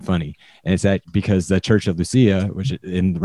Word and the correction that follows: Laodicea: Revelations, I think, or is that, am I saying that right? Is it Lucia? – Revelation. – The Laodicea. – Laodicea. Thank you. I knew I was missing some.